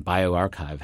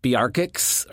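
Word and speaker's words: bioRxiv: Yeah, I asked people on Twitter because, you know, physicists know how to say archive, but you... One of the more minor issues about starting a preprint server is how to pronounce it. So I remember hearing somebody call BioArchive Biarchix or something like that.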